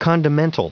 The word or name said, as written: condimental